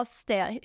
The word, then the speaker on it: Stamets: Paul Stamets. He has a great Stamets. Voice. – Did I pronounce it wrong? – Yeah, no, you pronounced it spot on, and singing it just made it that much more powerful.